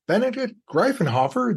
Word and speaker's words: Benedikt Greifenhofer: Today we're joined by a repeat guest, but who's got some great news, Benedict Greifenhofer, the CEO of GotPhoto.